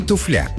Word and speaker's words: туфля: В действительности правильно говорить «туфля» с ударением на «у», и только так. При склонении ударение не смещается, соответственно, говорим «туфли», «туфлями», «о туфлях». Кстати, слово это в русском языке появилось не очень давно, в эпоху Петра I, и, скорее всего, заимствовано из немецкого или голландского.